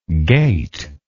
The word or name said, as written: gatelike